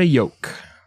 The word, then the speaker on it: Yoke: This time he was in love with a woman named Georgiana Yoke. Old Georgiana Egg Yolk. It's spelled Y O K E, not like yolk, like an egg, but that's it still sounds the same.